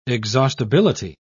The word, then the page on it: exhaustibility: British English